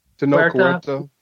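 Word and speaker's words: Tenoch Huerta: Tanok Huerta.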